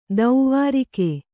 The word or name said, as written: ದ್ವಾರಿಗ